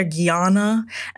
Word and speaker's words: Guyana: But secondly, there were two people who pronounced Guyana properly in the whole documentary. People see the name they want to add all these inflections to places where it's not their Guyana and whatnot. It's Guyana, point blank, period.